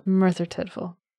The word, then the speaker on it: Merthyr Tydfil: Merthyr Tydfil.